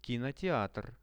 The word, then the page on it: kinoteatr: Russian